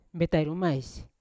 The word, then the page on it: betairumais: Yawa